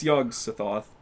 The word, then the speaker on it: Yog-Sothoth: I don't know how to fucking pronounce it. Yeah, it's Yogg-Sothoth, but it's like, do you ever play Skyrim? – Yep.